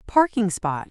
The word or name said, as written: parking spot